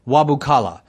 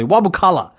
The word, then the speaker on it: Wabukala: Uh, there's an Archbishop of Kenya by the name of uh, um, uh, a Bishop or Archbishop rather uh, Wabakala. Wabukala. Um, I, I'm, I'm not familiar with his name. I don't say it very often, so I just wanted to make sure I say it correctly.